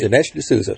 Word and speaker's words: Dinesh D'Souza: Dinesh D'Souza.